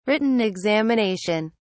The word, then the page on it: written examination: British English